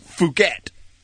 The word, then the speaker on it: Phuket: How do you say it? Phuket? – Right? – Thailand. – Oh, yeah. It is Phuket, yes.